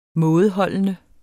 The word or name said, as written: mådeholden